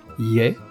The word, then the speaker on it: je: Ie, qui s'écrit J-E, c'est le joker, la préposition passe-partout que l'on emploie lorsque aucune autre ne convient.